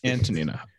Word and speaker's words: Antonina: I would like to see Antonia Shevchenko kind of use the Antonina. Pressure. Is that what I'm saying? – You pronounce my wife's sister's name right. – Antonina. I apologize.